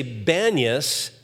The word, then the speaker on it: Banias: But the Arabs, they pronounce peas as bees. So they would say Banias, and so that's the name of that place to today. It's called Banias. And from that cave springs,